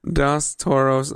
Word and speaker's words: Dos Toros: You pronounced it wrong. – Dos? – It's a burrito. Roll your R's.